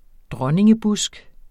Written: dronningebusk

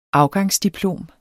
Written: afgangsdiplom

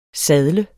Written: sadle

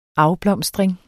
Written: afblomstring

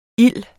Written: ild

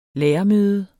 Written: lærermøde

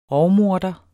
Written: rovmorder

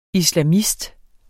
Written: islamist

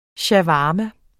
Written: shawarma

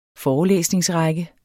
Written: forelæsningsrække

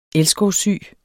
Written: elskovssyg

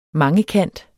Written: mangekant

